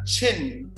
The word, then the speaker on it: chin: Ajá, chin, con CH, y luego la otra es shin.